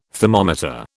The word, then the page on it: thermometer: British English